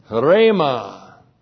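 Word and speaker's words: rhema (ῥῆμα): And the word for word is not logos, it's rhema. Rhema, that's the breathing mark, that's the letter H, R, long E, M, A. Rhema.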